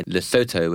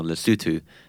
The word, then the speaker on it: Lesotho: And the last month had a good example of that, which was a 910-carat diamond that Gem Diamonds found at their mine, the Letseng mine in Lesotho, which I believe is the American pronunciation, or Lesotho, which is the British pronunciation.